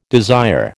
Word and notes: desire: American English

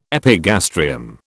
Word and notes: epigastrium: British English

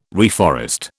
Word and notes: reforest: British English

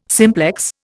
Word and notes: simplex: Indian

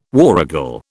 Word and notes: warragal: British English

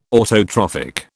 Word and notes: autotrophic: British English

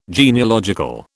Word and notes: genealogical: British English

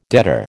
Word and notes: debtor: American English

